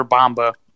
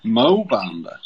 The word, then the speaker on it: Bamba: Muhammad Bama. Or Bamba. – Mo Bamba.